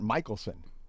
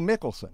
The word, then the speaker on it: Michelson: Well, you know that street in Irvine called, everybody calls it Michelson. – Mm-hmm. – And it's actually Mickelson. – Okay. – It's named after a guy named Mickelson.